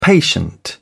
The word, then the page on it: patient: British English